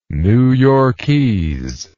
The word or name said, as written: New Yorkese